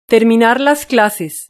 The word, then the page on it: terminar las clases: European Spanish